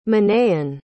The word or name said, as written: Manaen